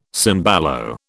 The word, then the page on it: cembalo: British English